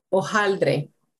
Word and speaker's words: hojaldre: they have something called hojaldre. The H is silent. It's spelled H-O-J-A-L-D-R-E. Hojaldre is basically a round flatbread that's fried until it's crispy.